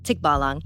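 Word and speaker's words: tikbalang: Tikbalong. You're close. – Oh, okay. I got to have that accent on there. It's what it is, right? – Yeah, it's on the second syllable.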